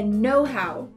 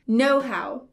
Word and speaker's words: know-how: Só que a pronúncia certa é know-how. Repeat with me know-how.